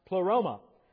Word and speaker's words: pleroma: Because the word fullness in the Greek is pronounced pleroma.